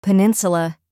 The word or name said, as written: peninsula